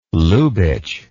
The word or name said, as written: Lubitsch